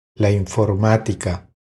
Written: La informatica

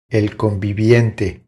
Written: El conviviente

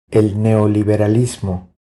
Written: El neoliberalismo

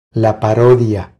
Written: La parodia

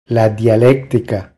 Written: La dialectica

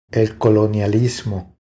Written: El colonialismo

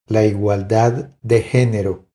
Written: La igualdad de genero

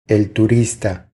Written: El turista

